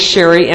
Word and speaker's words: Cherie: Team 12-Step Outreach Call with Sherry M. My name is spelled C-H-E-R-I-E.